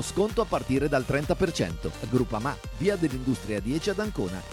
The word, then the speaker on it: Groupama: DJ Biancorosso, Groupama, si pronuncia Groupama, di Paolo Felicetti.